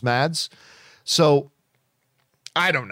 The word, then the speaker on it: Mads: Willow writes, I've just recently learned that the D in Mads Mikkelsen's name is apparently silent. So his name is actually pronounced Mass.